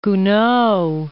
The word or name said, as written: Gounod